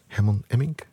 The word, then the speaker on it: Herman Emmink: Destijds groot geworden door Herman Emmink, zeg ik het goed? Jawel. Technicus Erik knikt goedkeurend, klopt.